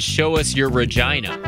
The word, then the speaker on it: Regina: But there's a town in Canada that's spelled R-E-G-I-N-A. So it's Regina.